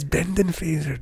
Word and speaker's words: Brendan Fraser: Brandon Fraser. – It's Brendan Fraser.